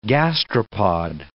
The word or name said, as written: gastropod